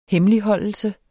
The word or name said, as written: hemmeligholdelse